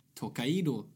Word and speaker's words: Tokaido: Sen vi spelade sist så har jag spelat eh, Tokaido, som det tydligen ska uttalas, mm.